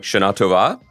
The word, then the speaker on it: Shana Tova: Did I pronounce that right? – No. – No. What? No. How do you say it? – Like Shana Tova? – Yeah, that's my bad.